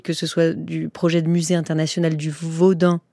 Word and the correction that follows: Vaudoun: Alors, pourquoi on met un N au Bénin ? – Vaudoun, pardon, il faut le prononcer comme ça.